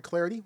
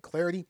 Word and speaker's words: Clardy: Follow me on Twitter at Troy Clarity. Last name is spelled C-L-A-R-D-Y at Troy Clarity.